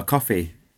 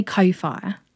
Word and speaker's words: Ko-fi: Is it pronounced Ko-fi or coffee? – I say Ko-fi. – I think Ko-fi sounds cooler, doesn't it? – Yeah, yeah.